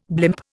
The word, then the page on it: blimp: Indian